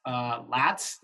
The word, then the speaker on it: Latz: Last name's pronounced Latz. Is that right? – Got it. Latz. Correct. – Latz.